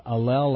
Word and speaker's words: allele: called allele, that's the way I'll pronounce it, A-L-L-E-L-E, Allele 334, bonded less with their mates and had more relationship conflict, according to the report.